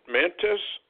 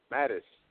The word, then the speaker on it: Mattis: Mantis? – Mattis.